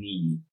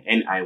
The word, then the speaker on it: Niyi: For short, you can just call me Ni. So my short name is yeah. N I Y I. So that's um Ni.